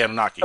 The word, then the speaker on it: Anunnaki: Oh, no, no, because I was going to say that, like, you had asked about, like, the opinion of the Anunnaki. Did I say that right? – Yeah, the Anunnaki, okay.